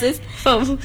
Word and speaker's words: foam: So the correct way to pronounce is foam.